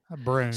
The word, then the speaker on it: Broan: By the way, I think when it comes to bathroom fans and exhaust fans, I think Brone makes 93.7% of all bathroom fans. – Spell that. – B-R-O-A-N. – B-R-O-A-N, Brone. – Yeah, Brone.